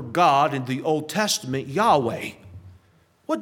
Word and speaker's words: Yahweh: There's no J in Hebrew. It's Yahweh. That's the proper pronunciation in English.